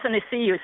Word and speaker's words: Athanasiou: Athanasiou. Think of it as see you later.